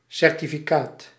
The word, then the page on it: certificaat: /ˌsɛrtifiˈkat/